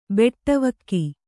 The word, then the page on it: ಬಟ್ಟೆವೋಗು: baṭṭevōgu